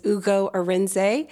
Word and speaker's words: Ugo Arinzeh: As you correctly pronounced, my name is Ugo Arinzeh, and I am founder and managing director of Onyx Property Consultants.